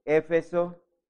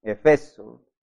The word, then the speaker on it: Éfeso: Volvamos a leer la carta, la escritura a la iglesia de Éfeso. Yo la pronuncio Éfeso como esdrújula. Hay otros que la pronuncian Efeso. No sé cuál es. Creo que es Efeso. Lo estaba pronunciando de pronto mal.